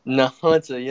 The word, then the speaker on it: Nahunta: So I hope I pronounced this correctly. Nahunta, Georgia? – Nahunta, yes, sir.